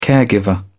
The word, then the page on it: caregiver: British English